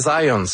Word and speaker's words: Zajonc: C'est Zajonc. – Ouais? OK. – J'ai-tu pas dit Zajonc? – Vous avez dit plus Zajonc, mais... – Parce que j'ai prononcé un petit peu à l'anglais. J'ai prononcé mon polonais à l'anglais.